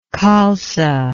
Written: Khalsa

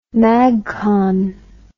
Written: magcon

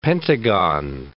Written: pentagon

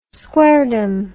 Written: squaredom

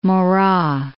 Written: Marat

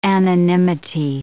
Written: anonymity

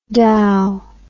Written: dauw

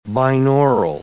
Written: binaural

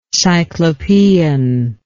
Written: cyclopean

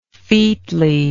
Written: featliness

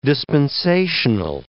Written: dispensational

